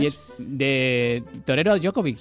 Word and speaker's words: Djokovic: Djokovic. – Djokovic. Bueno, ya de tres lo hemos dicho los tres. Tampoco debe ser tan meritorio, ni eres. – Porque estamos acostumbrados, pero si ves cómo se escribe...